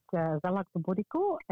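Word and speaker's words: galaktoboureko: And whether it's the flaunes, or I always call it galacta bereka, but what's the correct pronunciation of it? – Well, you did pretty well. That's pretty good. It's galacta bereka, and it's a Greek custard slice, if you like.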